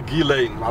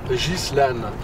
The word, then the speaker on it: Ghislaine: Die Amerikaner sagen alle Ghislaine, aber die Name spricht man aus Ghislaine.